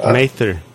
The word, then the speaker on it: Mather: How do you pronounce that? It's English. – Mather. Yeah.